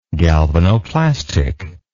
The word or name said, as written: galvanoplastic